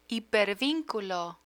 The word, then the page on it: hipervinculo: Spanish